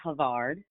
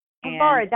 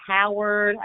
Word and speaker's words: Havard: I want to add an R there and make it Harvard. It's very hard. – Everyone does. So I pronounce it Havard. – Havard, that makes way more sense. Yes. – That's okay. I get everything. I get Howard. I get Harvard.